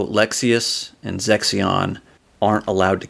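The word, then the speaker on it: Lexaeus: So, continuing on, Lexius, I'm saying that right. – Yeah, Lexius.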